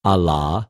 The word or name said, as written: ἀλλὰ